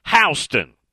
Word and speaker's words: Houston: Brett down in Georgia says, you don't say Houston down here in Warner Robins. You'll get corrected almost immediately. It's pronounced Houston.